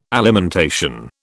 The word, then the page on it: alimentation: British English